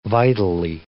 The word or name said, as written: vitally